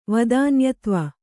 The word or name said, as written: ವದಾನ್ಯತ್ವ